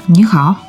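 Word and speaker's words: Нихао (你好): Нихао. – Нихао – это здравствуйте по-китайски? – Да, это традиционная форма приветствия в китайском языке. – Нихао. – Нихао. Здесь очень важна тональность произношения.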